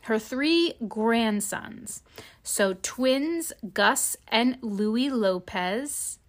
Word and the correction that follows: Lopes: No, that's not pronounced Lopez. I'm sorry. I was like, girl, her grandson's got the last name Lopez? No, It's Lopes. I pronounced it wrong. The twins are Gus and Louis Lopes, L-O-P-E-S, 13, and Freddie Parker Bowles, 13, as well as her great-nephew, Arthur Elliott, 10.